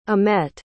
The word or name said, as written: amette